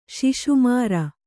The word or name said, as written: ಶಿಶುಮಾರ